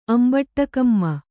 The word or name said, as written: ಅಂಬಟ್ಟಕಮ್ಮ